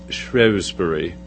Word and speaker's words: Shrewsbury: And I had the opportunity in February to actually speak in what I thought from the map was Shrewsbury, his birthplace, but I learned when I was there it's pronounced Shrewsbury. Okay, it's a British thing.